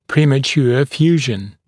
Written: premature fusion

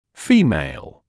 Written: female

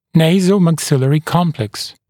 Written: nasomaxillary complex

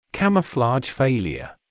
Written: camouflage failure

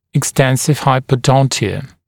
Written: extensive hypodontia

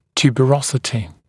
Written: tuberosity

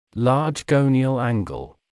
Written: large gonial angle

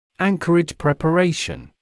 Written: anchorage preparation

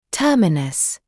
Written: terminus

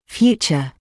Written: future

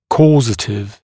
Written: causative

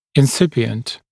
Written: incipient

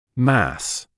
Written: mass

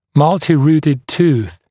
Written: multirooted tooth

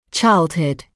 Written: childhood

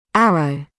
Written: arrow

